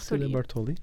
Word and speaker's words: Cecilia Bartoli: I'm going to probably not say her name properly. Cecilia Bartoli. – Uh-huh. – Bartoli. – Cecilia Bartoli. – Yeah, exactly.